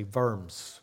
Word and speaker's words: Worms: I think of Martin Luther at the Diet of Worms, or they say Worms is the correct way to say it.